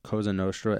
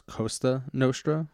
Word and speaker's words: Cosa Nostra: He mispronounces Cosa Nostra as Costa Nostra, which, regrettable typo.